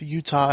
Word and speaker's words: Eutaw: And also, it is not spelled like the state. It is actually exactly. – E-U-T-A-W.